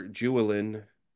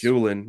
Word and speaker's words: Julin: Julian's gonna find, or Julin is how it's spelled. – It's yeah, Julin is how it's Julin, yeah.